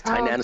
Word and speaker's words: Tainan: That was in Tainan. Is that how you pronounce it? – Yes, Tainan is perfect.